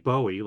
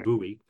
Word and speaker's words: Bowie: spelled like Bowie, like David Bowie, but it's pronounced Bowie.